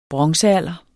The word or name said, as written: bronzealder